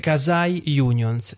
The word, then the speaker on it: quasi unions: definisce delle casai unions. Si scrive quasi unions e vuol dire effettivamente quasi sindacati.